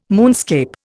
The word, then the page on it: moonscape: Indian